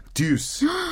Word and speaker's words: Deux: It is Deuce. – The Deuce. – Yeah, so it is D-E-U-X, by the way. – Which is do. – Do, yeah. – But they're pronounced, it's pronounced Deuce.